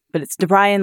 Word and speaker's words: DeBryan: No, there's no way to tell how it's pronounced. It's DeBryan, like the boy's name, Brian.